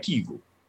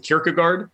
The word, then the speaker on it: Kierkegaard: So it ends up being in, you know, less Kierkegaard and it becomes more like Kiegel. And so given the fact that every native Danish speaker I've heard pronounce his name was not the thing that the kind of fancy Kierkegaard scholars say. – Yeah. – I thought, you know, I'm from the South. I drive a lifted truck and I love this guy's name that means churchyard. And so I still pronounce it Kierkegaard.